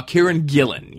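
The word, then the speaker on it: Kieran Gillen: Is that how you say his name? Gillian? – Kieran Gillian. Yes.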